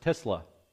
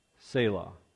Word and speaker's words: Selah: I've heard it pronounced Selah, and I've heard it pronounced Tesla. Okay? Because that's what it is. Yeah, but the pronunciation is Selah. Okay? So if I say Tesla, that's just a variation of the word.